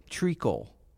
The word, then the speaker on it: treacle: Oh, treacle. That's how you say it.